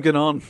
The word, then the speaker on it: Gagnon: No, you said Gainon, but I want to say Duganon. – Ganon. Yeah, Ganon. It's G-A-G-N-O-N. Gagnon. – Gagnon. – Let's call him Gagnon.